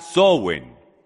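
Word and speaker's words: Samhain: Se pronuncia Sowin.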